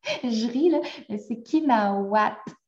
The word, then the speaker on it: Kina8at: Puis là, je dis C'est parce que le 8, pour ceux qui ne savent pas, Kinawat, ça s'écrit avec un 8, donc K-I-N-A 8-A-T mais le 8 est vraiment un W dans l'alphabet algonquin.